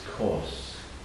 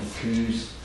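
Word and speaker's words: Coos: I know some people want to pronounce this place Kos. I think Kuz.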